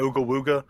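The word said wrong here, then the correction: Uiagalelei: is – Ogalele. – Ogalele, yeah, that's how you say his name.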